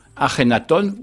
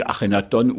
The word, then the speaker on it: Akhenaton: C'est ce qu'on appelle l'épisode amarnien avec le pharaon Akhenaton ou Akhenaton. On dit Akhenaton ou Akhenaton selon qu'on veut avoir une prononciation qui soit plus ou moins proche de la réalité égyptienne.